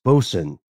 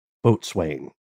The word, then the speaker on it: boatswain: and said it's pronounced bosun, not boatswain.